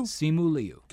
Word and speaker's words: Simu Liu: Simu Liu? – Simu Liu.